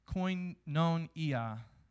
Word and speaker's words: koinonia: And the Greek word for that is koinonia. Koinonia. That's exactly how to say it.